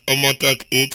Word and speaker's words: Omotalk: At Omotalk8.